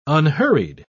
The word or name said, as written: unhurried